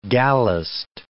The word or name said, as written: gallused